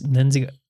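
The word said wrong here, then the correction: Knudsen: Nudsen. – Nudsen, like the cottage cheese.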